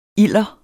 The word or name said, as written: ilder